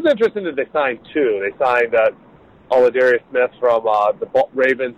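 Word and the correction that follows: Za'Darius: Say his name wrong, right? – Lada- no, no, yeah, uh, you're right. It is Zadarius Smith? – I'll oh, say Zadarius Smith, and yeah.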